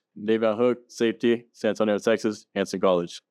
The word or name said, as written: Daveon Hook